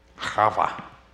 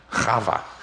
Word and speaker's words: Chava: Do you remember the Hebrew word for Eve? Chava. Say it, it's fun. Chava. You'll know you said it right if a little piece of stuff comes out of your eye.